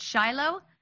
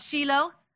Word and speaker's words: Shiloh: One thing that I've always pronounced it Shiloh, but I found out when we got there, it's actually called Shiloh.